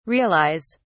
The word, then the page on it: realise: British English